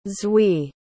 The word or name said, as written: zwi